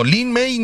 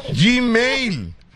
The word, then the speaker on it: Gmail: No, Lin May no. No, Lin, no, no, no. Lin May no. Jim. Gmail.